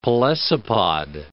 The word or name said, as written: pelecypod